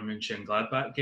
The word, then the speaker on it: Mönchengladbach: I watched about 10 minutes of the Frankfurt Borussia Mönchengladbach game, and I think they were excellent pronunciation there, by the way.